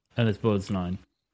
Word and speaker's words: Borgnine: Ernest Borgnine.